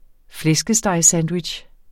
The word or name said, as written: flæskestegssandwich